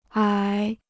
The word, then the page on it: 矮: ǎi